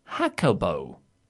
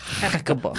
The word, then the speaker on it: Jacobo: My name is pronounced Bobo Jacobo. It's Spanish. Ah, I said Jake Jacobo. So Jacobo. – Jacobo. – Yeah, there we go.